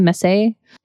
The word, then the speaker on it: Messé: Yeah, and just so everybody knows, it was Daniel Mess, I think is how you pronounce his last name, Messé, and Nathan Tyson, who are part of a folk band out of, I think, Brooklyn.